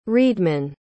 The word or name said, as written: reedman